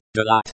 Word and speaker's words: делать: Pronounced делать, the, as in, they, lat, as in, lathe, with the, th, sound very light.